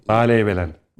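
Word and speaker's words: Bâleybelen: Mesela bu e, Baley Belen doğru te- telaffuz ediyor muyum hocam? böyle? – Baley Belen.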